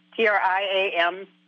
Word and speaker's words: triamcinolone: I'll spell it because I can't pronounce it. TRIAMCINOLONE. – Triamcinolone. – There you go.